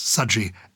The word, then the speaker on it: Sudgy: I think it's Sudgy as in budgie.